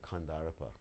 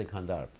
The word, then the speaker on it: Kandarp: I didn't say Kandarp, because you don't pronounce the final A in Hindi. You've taken the Sanskrit and ruined it. So, you understand, the final A is not there. Like Vrindavan, Vrindavana. So you say Kandarp, and I say Kandarpa.